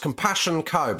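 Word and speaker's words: CMPSSN Co: It's pronounced Compassion Co, but it's spelled C-M-P-S-S-N Co, like compassion without the vowels. – Compassion, C-M-P-S-S-N Co.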